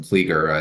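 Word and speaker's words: Klieger: Now, after law school, you became an attorney at Kendall, Brill, and Kelliger. Did I say that right? – It's Kendall, Brill, and Kleeger.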